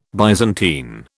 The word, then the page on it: Byzantine: British English